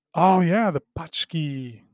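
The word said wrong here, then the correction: pączki: Punchki. – Punchki? – Yeah. – There's no N. – I know, but that's how it's pronounced.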